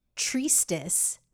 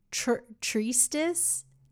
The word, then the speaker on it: treatise: It's treatise, not treatise. No, it's not treatise. It's treatise. Oh my goodness. Treatise. I speak English.